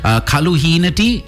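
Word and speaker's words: kaluheenati: Then you have the traditional range, suadel, kaluhinati. Did I say that right? – Yes.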